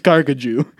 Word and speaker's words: Carcajou: Carcajou, which I actually used the Google like speech thing to figure out how to pronounce this, and it is just Carcajou, is the French word for Wolverine.